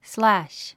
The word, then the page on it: slash: slǽʃ; スラァッシュ